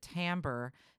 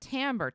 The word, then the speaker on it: timbre: Not only will nobody understand what you're talking about, but also we specifically pronounce it timbre in American English to dis- dis- dis- specifically distinguish it and differentiate differentiate it from the other English word that is spelled timber, which is lumber, uh, and wood, T I M B E R, which is more much more phonetically uh, spelled.